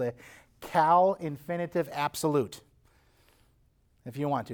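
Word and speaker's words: Qal: It's what we call in grammar, again, elementary school, middle school grammar that might cause us to, you know, shake a little.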